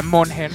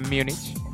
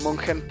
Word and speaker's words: München: No se pronuncia Monchen en vez de Múnich. Es alemán, ¿no? – Bayern München. Pues empezamos con el fútbol, ¿verdad? – Sí, sí.